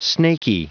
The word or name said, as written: snakey